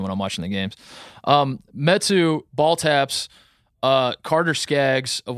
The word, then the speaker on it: Metu: It's Metu, yes.